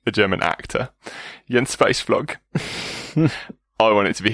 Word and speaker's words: Jens Weissflog: Jens Weissflog.